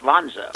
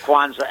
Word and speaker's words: Kwanzaa: For those that celebrate it, I wish a happy Schwanza, which I think is just, I don't know if you picked up her comment. – I did. – She mispronounced Kwanzaa as Schwanza, you know, without even missing a beat, so...